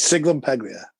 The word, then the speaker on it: Sciglimpaglia: Sciglimpaglia.